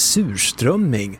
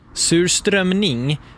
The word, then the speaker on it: surströmming: En pojke döms nu för att ha angripit en lärare med surströmming. – Va? – Mm. Men två saker. För det första då, uttalet på surströmming. – Surströmming? – Surströmning sägs.